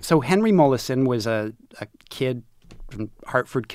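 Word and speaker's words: Molaison: Mollison.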